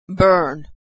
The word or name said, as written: burn